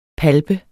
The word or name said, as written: palpe